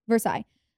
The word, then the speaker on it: Versailles: How do you say that? – Versailles.